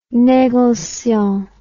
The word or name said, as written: negociant